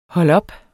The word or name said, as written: holdop